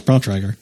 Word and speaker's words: Bontrager: One of the newest developments, it's from Trek Bikes and Bontrager. How do you pronounce Bontrager? – Yeah, that's it, Bontrager. – Bontrager. – At least that's the way I pronounce it.